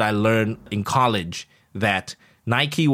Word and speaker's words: Nike: And, you know, the better part of my childhood, to me, N-I-K-E is Nike, right? N-I-K-E is Nike. For a better part of my childhood, I pronounced it Nike. And so did all my friends. Until...I went to a store and everybody's like, Nike, Nike. And then I'm like, what the hell is Nike?